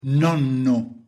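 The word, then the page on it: nonno: Italian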